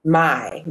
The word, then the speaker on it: Mai: Mai.